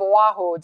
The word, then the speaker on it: Oahu: Two are now in college, and you're raising tropical fruit on the north shore of Oahu. Did I say that right? – You did. Very well done.